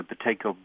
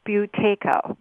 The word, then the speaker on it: Buteyko: Now, you pronounce that Buteyko. – Yeah, like in the Halloween Buteyko. It's B-U-T-E-Y-K-O.